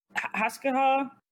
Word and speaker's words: Hasgaha: Basically just of the, I think, was it Hasgaha? I'm already butchering the name. – No, you got it.